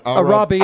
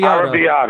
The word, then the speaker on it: Arrabbiata: I couldn't pronounce this one. – Arrabbiata. – Arrabbiata. Arrabbiata. – That's because you're not Italian.